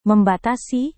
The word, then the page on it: membatasi: Indonesian